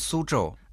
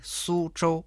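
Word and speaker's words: Су-чжоу (苏州): «Су» читается в первом тоне, «чжоу» тоже в первом тоне. Повторите за мной. «Су-чжоу».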